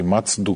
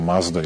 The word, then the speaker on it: Mazda: obišao dakle i Mazdu, kako oni izgovaraju, a mi pogrešno kažemo Mazda ili već kažemo kako je nama prirodnije da izgovaramo, i video koliko su vredni i koliko toga možemo da naučimo od njih.